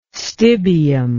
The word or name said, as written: stibium